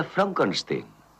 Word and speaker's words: Frankenstein: No, se pronuncia Frankenstein.